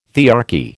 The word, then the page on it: thearchy: American English